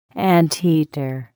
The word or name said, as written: anteater